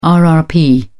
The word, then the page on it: rrp: British English